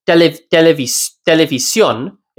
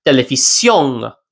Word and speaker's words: televisión: in many countries, the N changes sounds. It actually isn't pronounced as a typical N. So a word like televisión means television, instead of being pronounced televisión, it'll be pronounced televisión. It's kind of in the back of the mouth. It's a different sound.